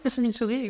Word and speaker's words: chimichurri: tu vas peut-être mieux le prononcer que moi, de chi, chimichurri. Oui, c'est la sauce chimichurri, exactement.